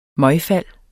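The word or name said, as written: MØGFALD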